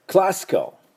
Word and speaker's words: classical: で、あと最後にですね、 Classical。 Classical はクラシックに、クラシまで行って最後あの CAL ですから、 CLASSIC CAL ですが、クラシカルと、あ、ほとんど発音しません。クラシカル、ね、ちょっと出すだけか、またほとんど出さないと、まあ、あの辞書を見ると括弧半母音になっております。